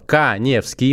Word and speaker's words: Каневский: Заходите в инстаграм, находите меня, Эдуард Каневский, моя фамилия пишется через А,